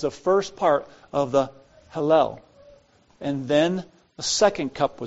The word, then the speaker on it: Hallel: Then the first part of the Hallel, which is Psalm 113 to 118, was sung. Hallel. What do you suppose that means? H-A-L-L-E-L. I think that's how you pronounce it. Hallel. Think of one of the songs we sang tonight. H-A-L-L-E-L.